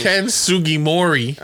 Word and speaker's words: Ken Sugimori: Ken Sugimori.